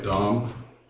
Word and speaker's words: Dam: Dwight Dom. My last name is spelled D-A-M.